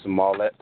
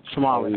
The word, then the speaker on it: Smollett: Jesse Smollett. – Smollett.